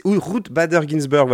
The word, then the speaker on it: Ruth Bader Ginsburg: Vous en avez peut-être entendu parler aux infos, mais Ruth Bader Ginsburg, je ne sais, jamais, c'est Ruth Bader Ginsburg, voilà, je me bien prononcer son nom, est morte le 18 septembre, donc ça fait deux jours maintenant.